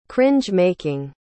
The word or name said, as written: cringe-making